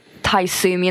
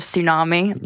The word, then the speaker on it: tsunami: Does anyone else out there say Pacific instead of specific, specific. – or say Taisumi instead of tsunami? – That's just you. That's just me. I say it like it's typed.